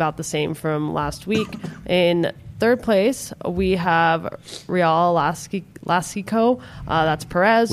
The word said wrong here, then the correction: Jalisco: I said it wrong, I think. Real Alaska. – Jalisco? – Jalisco. There we go.